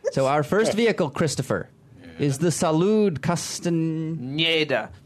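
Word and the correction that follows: Castañeda: Pronounce the tilde. – Nieda. – And the Salud Castaneda.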